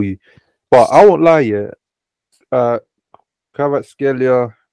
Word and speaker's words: Kvaratskhelia: Kvaratskhelia, no? – That's how you say it.